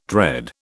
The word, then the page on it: dread: British English